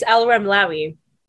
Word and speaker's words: Alramlawi: Please say your last name for me so I don't butcher it. – Yeah, absolutely. It's Alramlawi.